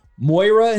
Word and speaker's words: Moira: And now, I'm not of a gamer, so make sure I'm pronouncing this right. Moira in the house? Yeah. Am I right on that? – That is correct.